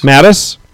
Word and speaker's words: Mattis: Mattis.